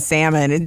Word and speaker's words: salmon: Anyway, I saw this meme the other day that said, you might as well just go ahead and pronounce the L in salmon.